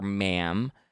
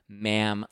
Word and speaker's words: ma'am: And then after that, you only call her ma'am because it rhymes with ham. And he writes that in the book, ma'am like ham, because, you know, he says, I guess we don't have manners in the U.S., so we don't call people ma'am.